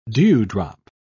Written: dewdrop

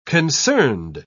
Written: concerned

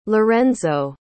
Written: larenzo

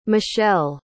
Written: michele

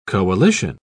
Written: coalition

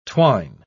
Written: twine

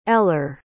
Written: ellar

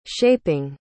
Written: shaping

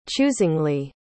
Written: choosingly

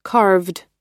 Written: carved

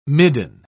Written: midden

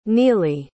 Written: neely

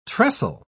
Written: trestle

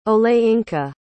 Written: olayinka

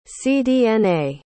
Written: cdna

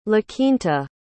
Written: laquinta

